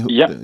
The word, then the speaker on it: Hublot: Do you say Hublot? How do you say Hublot? Yeah.